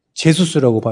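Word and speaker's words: Jesus: 바르셀로나에도 가브리엘 지저스라는 애가 있어요. 제수스라고 발음해요. 걔는요.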